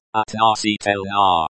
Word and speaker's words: относительно: The stress in относительно. Относительно falls on the third syllable.